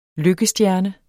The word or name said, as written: lykkestjerne